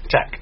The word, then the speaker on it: Tech: Tekken is definitely still in the game. And Ukemi. – Tech. Yeah, you're pronouncing wrong. Tech.